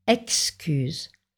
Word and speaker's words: excuse: Excuse, on prononce ex, excuse.